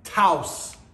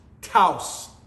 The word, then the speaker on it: Taos: Have you ever been to a little town in New Mexico called Taos? Taos. Probably going to say that wrong throughout this message, but that's how you say it. Taos.